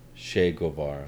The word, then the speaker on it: Che Guevara: Shave Gold. What's his name? – Che Guevara.